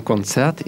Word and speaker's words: Konzert: Ага, да, значит, в концерте ударение как в русском концерт, в Берлине как в русском Берлин. Ein Konzert in Berlin.